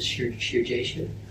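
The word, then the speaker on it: Shearjashub: Her name is Sherjasha. – How do you spell that? – S-H-E-R-E-A-R. – S-H-E-R? – S-H-E-A-R, Sheer. Jash. Jashub is J-A-S-H-U-B. – J-A-S-H-U-B. – Sure, Jashub.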